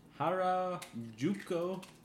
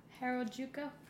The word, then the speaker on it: Harajuku: The latest sex fetish in the Harajuku neighborhood. – Harajuku? – Harajuku.